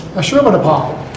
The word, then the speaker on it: Ashurbanipal: The authors of the letter identified themselves as descendants of those deported by Aherbanel. No, say that. How do you pronounce that word? – Ashuras? – Ashurbanipal. – No? Oh, Ashurbanipal. – That sounds right. – There you are, Ashurbanipal.